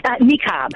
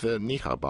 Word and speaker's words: niqab: And by the way, how many different ways is this word pronounced? Niqab? I mean, what are we saying here? – Niqab. – Okay. – It's pronounced niqab. – Okay. But isn't that just the anglicized way of pronouncing it? Isn't it niqab? Don't you have to go, I don't know if the Q has a h sound.